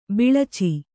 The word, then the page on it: ಬಿಳಿಚ: biḷica